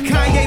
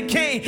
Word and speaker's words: Kanye: All I need is y'all to pronounce my name. Kanye, but some of my plaques, they still say Kane.